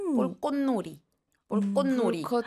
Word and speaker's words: 불꽃놀이: 왜냐면 일단 쌍기역이 들어가고 그러니까 쌍기역 중간에 이렇게 들어가면은 저는 신경 안 쓰면 발음이 좀 잘 안 될 때도 있어요. 불꽃놀이. 불꽃놀이. – 앞에 받침이랑 붙어서 더 어렵겠다. – 맞아요. 그리고 밑에 보면 볼꽃이잖아요. 네. 근데 또 이렇게 이어서 말하면 볼꽃놀이. – 귀여워. – 꽃놀이 되잖아요.